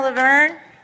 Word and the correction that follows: Luverne: Did I say that right? No? – That's okay. – correct me. – It's Luverne. – Luverne. Okay. Got it.